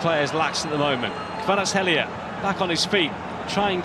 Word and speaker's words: Kvaratskhelia: Kvaratskhelia, nice.